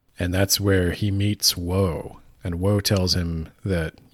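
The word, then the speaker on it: Wo: Woe and Shade. – Woe and Shade. So that's not ominous. But it's not spelled like the word woe, it's just W-O.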